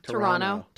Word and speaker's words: Toronto: Nobody in Toronto pronounces the second yep. – T. Yeah. – What do they say? – Toronto. – Toronto? – Yeah. – Oh, yeah. Well, it's that sort of soft D.